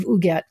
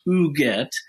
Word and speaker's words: Huguette: And yes, the correct French pronunciation is Huguette, although we American cousins always call her Huguette. Either one is acceptable to us.